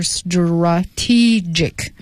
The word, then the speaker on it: strategic: 啊，这个 TR 呢不念成 tra，而念成 dra，浊化的是 strategic。